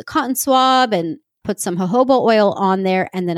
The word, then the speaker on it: jojoba: I was using jojoba oil, which jojoba is spelled J O J O B A. So you've probably seen it, but it's pronounced jojoba.